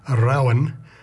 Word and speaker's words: Rowan: Rowan as in rowing, or yes, rowing, that'll do, but it's pronounced Rowan.